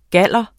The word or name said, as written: galder